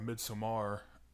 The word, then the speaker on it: Midsommar: S- um, speaking um, of horror, we talked about doing one for uh, Midsommar, and uh, is that how it's pronounced? – It is.